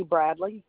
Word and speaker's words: Bradley: Bambi Bradley. And it's Bradley? – Yes, ma'am. – B-R-A-D-E-L-Y? – B-R-A-D. – B-R-A-D. – B-R-A-D-L-E-Y.